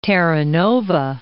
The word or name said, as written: Terra Nova National Park